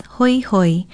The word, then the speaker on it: hoihoi: If you do, the word to describe your feelings is hoihoi. Hoihoi is spelled H-O-I-H-O-I.